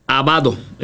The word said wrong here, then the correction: Abdo: ¿es correcto? – Bueno, José Abdo. – Abdo, con la B, Abdo.